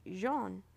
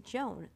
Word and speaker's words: jaune: The word for yellow is jaune. Only I pronounced it Joan, like her name.